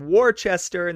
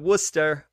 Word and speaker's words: Worcester: I said Worcester instead of Worcester or Worcester.